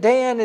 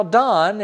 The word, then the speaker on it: Dan: Just like it was in Dan, it's not Dan, it's Don. In G-A-D, the Hebrew name that you would call Gad if you'd pronounce it in English, is pronounced Gad in Hebrew.